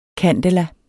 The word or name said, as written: candela